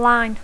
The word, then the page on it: line: English